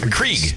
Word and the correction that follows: Keurig: Keurig. – Keurig. – Yeah. You don't even know how to say it.